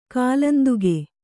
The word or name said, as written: ಕಾಲಾಂತಕ